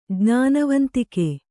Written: ಜ್ಞಾನವಂತಿಕೆ

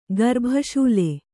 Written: ಗರ್ಭಶೂಲೆ

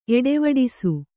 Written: ಎಡೆವಿಡಿಸು

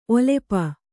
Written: ಒಲಪು

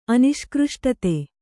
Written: ಅನಿಷ್ಕೃಷ್ಟತೆ

